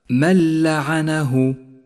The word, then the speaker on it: من لعنه: والواجب هنا أن نسقط النون من اللفظ بشكل كامل وأن نشدد حرف اللام الذي بعدها، ولذلك لونت النون باللون الرمادي إشارة إلى عدم نطقها، فيصير اللفظ هكذا من لعنه من لعنه.